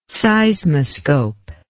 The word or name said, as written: seismoscope